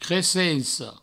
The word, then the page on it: cresenca: cresenco